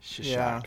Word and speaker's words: Cheshire: Cheshire cat.